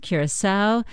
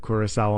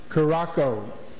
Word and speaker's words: Curaçao: Before the tournament, most people couldn't find the small Caribbean island of Curaçao on a map. Even hockey great Wayne Gretzky struggled to pronounce the Dutch territory's name right during December's World Cup draw. – Caracos.